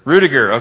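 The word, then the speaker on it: Ruettiger: His official name is Daniel Eugene Ruttinger, if I'm saying that correctly. What's that? – Rudiger. – Okay, thank you. Rudiger, very good.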